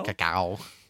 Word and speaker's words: cacao: How do you say that? Cacao? – Cacao.